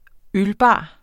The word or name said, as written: ølbar